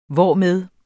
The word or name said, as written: hvormed